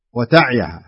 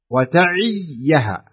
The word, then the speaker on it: وتعيها: المشكلة أن الناس ما ينطقون الياء فتحصلهم يقرؤونها بهذه الطريقة الخطأ يقرؤونها وتعيها ها والصحيح يسكن العين والصحيح أن يكسر العين وينطق الياء وتعيها كذا